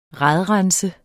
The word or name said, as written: RADRENSER